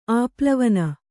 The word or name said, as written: ಆಪ್ಲವನ